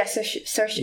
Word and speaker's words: Saoirse: Yeah, Saoirse. – Yeah, yeah. It's Saoirse? A really hard word to say, her name. I only know because she says it rhymes with inertia.